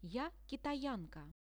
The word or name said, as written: ja kitayanka